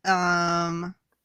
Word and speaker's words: Nihilus: They are discussing Nihilus.